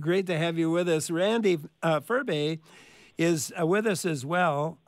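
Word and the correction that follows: Ferbey: Furby, okay.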